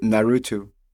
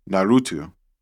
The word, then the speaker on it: Naruto: Naru- Naruto. Naruto.